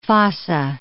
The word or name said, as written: fossa